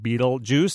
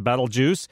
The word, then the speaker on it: Betelgeuse: It's called Betelgeuse, sometimes pronounced Betelgeuse.